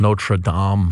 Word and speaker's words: Notre Dame: Now, is it Notre Dame over there, or is it Notre Dame? – Notre Dame. – Notre Dame. – Notre Dame. – French pronunciation.